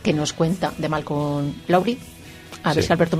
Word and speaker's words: Lowry: Lowry, pero no, es Lowry.